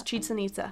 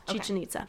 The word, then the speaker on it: Chichen Itza: Yes, Chichen Itza. – Okay. – Yes. – Is that how you say it? – Yes, Chichen Itza.